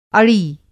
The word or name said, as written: अड़ी